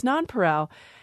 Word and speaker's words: nonpareil: It is pronounced nonpareil, but for people who know French, you look at it and think maybe it's pareil, because in French you wouldn't pronounce the L, or maybe it's nonpareil.